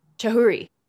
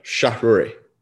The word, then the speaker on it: Chahrouri: Say your last name for me one more time. – All right, here we go. Chahrouri.